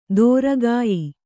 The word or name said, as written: ದೂರಗೆಯ್ಯು